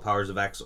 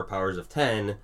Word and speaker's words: Powers of X: That is, you know, one of the questions, should this be called Powers of X or Powers of 10? – What? – And that's a good question. He goes, it's pronounced Powers of 10. You'll understand why when you read the series.